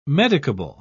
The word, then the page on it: medicable: British English